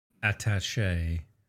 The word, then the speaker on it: attaché: Attaché.